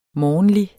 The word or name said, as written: morgenlig